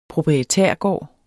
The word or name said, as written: proprietærgård